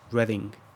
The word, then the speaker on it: Reading: Acabamos de pasar una ciudad que se llama Reading. Se escribe como Reading, el gerundio del verbo leer en inglés, pero se pronuncia diferente.